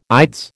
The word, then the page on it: ides: American English